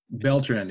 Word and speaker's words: Beltran: Beltran, yeah.